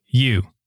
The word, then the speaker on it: ewes: I want you to pronounce this because the way I would pronounce it, it's probably not right, but it's E-W-E-S. I would call them ewes. – It's you.